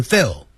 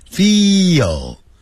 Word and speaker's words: feel: If we still don't feel well, 如果我们仍然没有感到好的话， feel, 不要念成 feel, 要念长元音， feel, feel.